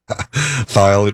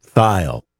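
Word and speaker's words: Thayil: Thyle, it rhymes with smile, I suppose. – Yeah. – T-H. – Okay. – Thyle. Kim Thyle. All right. We'll remember that. You just think thigh, too, right?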